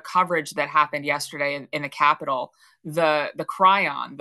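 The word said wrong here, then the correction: chyron: the description on the bottom, even on Fox News, said Jewish Chiron. – Groups. Chiron. Thank you. I can never say that correctly.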